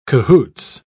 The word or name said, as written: cahoots